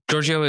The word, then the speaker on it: Georgio: Georgio is the correct pronunciation of my last name.